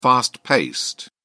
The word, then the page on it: fast-paced: British English